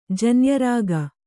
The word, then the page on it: ಜನ್ಯರಾಗ: janya rāga